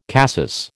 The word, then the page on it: cassis: American English